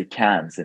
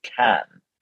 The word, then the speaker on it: Cannes: By the way, we don't say Cannes in French. We don't pronounce the last letters. Remember, we say Cannes. It's not Cannes, the city of the film festival.